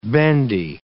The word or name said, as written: bendy